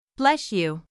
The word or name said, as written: Bless you